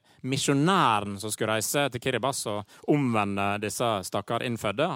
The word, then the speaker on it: Kiribati: Jeg hadde sagt Kiribati om jeg fikk valget, men det er ikke sånn det skal sies. – Nei, det er riktig. Og det uttales rett og slett Kiribas.